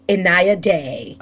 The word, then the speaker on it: Inaya Day: So please tell me, how do I correctly pronounce your name in case I butchered it? – Inaya Day. – Okay, perfect. I was correct, so I got it right.